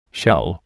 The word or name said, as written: shell